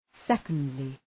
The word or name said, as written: secondly